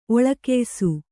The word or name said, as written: ಒಳಕೆಯ್ಸು